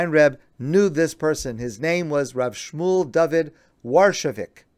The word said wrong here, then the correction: Warshavshik: I'm sorry, I think I mispronounced that. Rav Shmuel David Warshavshik. I apologize. Warshavshik.